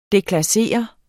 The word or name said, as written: deklassere